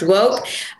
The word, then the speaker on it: WOCC: So that's WOCC, pronounced Woke.